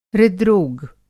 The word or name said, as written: हृद्रोग